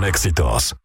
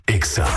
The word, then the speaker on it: EXA: No se dicen éxitos, se dice EXA.